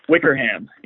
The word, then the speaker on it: Wickerham: Now, do you pronounce it Wickerham, or like an English person would, or Wickerham? – Wickerham. Yep, you got it right the second time.